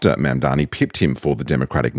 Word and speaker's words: Mamdani: The name is Mamdani, M-A-M-D-A-N-I. You should learn how to say it because we got to get it right.